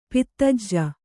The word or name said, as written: ಪದಾಜಿ